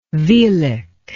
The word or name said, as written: velic